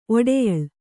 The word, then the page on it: ಒಡೆಯಳು: oḍeyaḷu